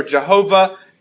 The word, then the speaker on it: Jehovah: We pronounce it Yahweh or Jehovah.